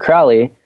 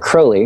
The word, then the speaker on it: Crowley: And the last one I listened to was mostly about Aleister Crowley, or Crowley, excuse me. Apparently that's the way you pronounce it.